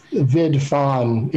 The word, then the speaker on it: vidphone: So there are signs that said vidphone, if you pronounce it how it's spelled, because there's no E.